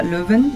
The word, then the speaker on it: Leuven: Leuven, l'ho detto bene? – Sì, sì.